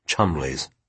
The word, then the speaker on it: Chumley: Penny gave it the correct English pronunciation. "Chumley's."